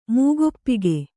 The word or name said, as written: ಮೂಗೊಪ್ಪಿಗೆ